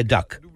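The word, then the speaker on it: DUKW: Tenhle obojživelný stroj se jmenuje Duck. Píše se DUK2V, ale vyslovuje se to duck jako kachna v angličtině.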